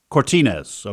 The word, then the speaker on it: Cortines: And John, how do you spell the last, how do you pronounce the last name of your co-author, your friend, John? – Cortines. – Okay.